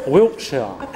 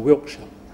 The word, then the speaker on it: Wiltshire: I said, no, Wiltshire. So that's why, from that day forward, I've always said, like a BBC proper pronunciation, Wiltshire.